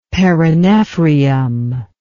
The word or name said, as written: perinephrium